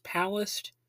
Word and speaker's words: Palast: And I've also heard it pronounced Palast, but I think it's pronounced Palast because in an interview that Jello Biafra did with him, he regularly pronounced it like that. And Greg didn't correct him or anything like that, so I'm just gonna say like Palast.